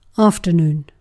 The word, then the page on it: afton: [ˈafˌtɔn]